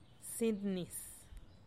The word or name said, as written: sintnis